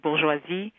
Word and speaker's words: bourgeoisie: It's a sort of new kind of bourgeoisie. – Thank you for your French pronunciation of bourgeoisie.